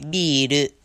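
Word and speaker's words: biru (ビール): And they don't say beer here, they say biru. So if you wanted to order two beers, you'd say futatsu biru kudasai.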